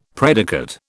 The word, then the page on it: predicate: British English